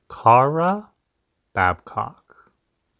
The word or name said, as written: Kara Babcock